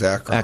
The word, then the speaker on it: Akron: Pronouncing your city correctly, which is Akron.